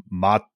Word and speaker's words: mate: The pronunciation, you said it's mate? – Mate, yeah.